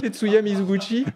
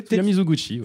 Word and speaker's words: Tetsuya Mizoguchi: Et moi, je dis, Il déjà écorché. Avant ah Tetsuguchi. Tetsuya Mizoguchi. c'est bien, je l'ai. Tetsuya Mizoguchi. Tetsuya Mizoguchi, bah, c'est facile comme tout.